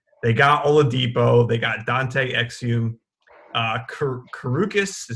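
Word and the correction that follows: Kurucs: Is that I'm not even sure how you say his name, but uh, Karutz.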